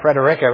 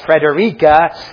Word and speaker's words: Frederica: I said it right, Frederica, right? Not Frederica, and it's Townsend and not Townsend.